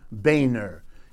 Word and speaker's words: Boehner: As if to drive the point home, let me tell you about former Speaker of the United States House of Representatives, John Boehner. He doesn't spell it that way, but he wants it pronounced that way, because if you didn't, it would be embarrassing.